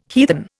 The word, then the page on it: heathen: Indian